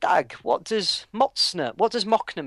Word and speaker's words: Mocne: it's pronounced Mocne, but it's spelt M-O-C-N-E.